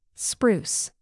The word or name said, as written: spruce